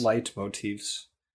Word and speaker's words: Leitmotifs: The soundtrack features, I don't know how to pronounce this, Leitmotifs, Leitmotifs. – Light motifs.